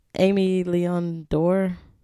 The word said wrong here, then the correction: Aimé Leon Dore: Aimé Leon Dore.